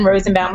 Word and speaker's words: Rozenbaum: So it's Jen Rozenbaum with a Z.